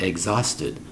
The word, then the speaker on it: exhausted: E-X-H-A-U-S-T-E-D. Exhausted. Now, looking at the word, you might say exhausted. It's pronounced exhausted. The H is silent.